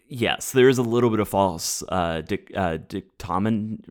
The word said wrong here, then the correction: dichotomy: Oh, dichotomy? – Oh, thank you.